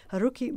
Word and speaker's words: Haruki: So just to let you know, Haruki Murakami is a Japanese author. His name is spelled H-A-R-U-K-I, Haruki. And his surname is Murakami, M-U-R-A-K-A-M-I, Murakami.